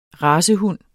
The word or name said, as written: racehund